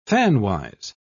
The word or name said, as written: fanwise